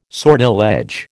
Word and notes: sortilege: American English

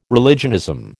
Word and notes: religionism: American English

